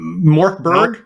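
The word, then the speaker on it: Mörk Borg: It's, I feel like that and Mork Pork. Am I saying it right? – Morkberg? Morkberg? – Yeah.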